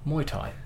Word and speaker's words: Muay Thai: However, okay, according to the pronunciation on the Wikipedia page about the sport, and a vast majority of professionals that I did come across online, it is pronounced Muay Thai.